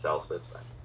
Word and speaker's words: Celsus: Sometimes it's pronounced Celsus.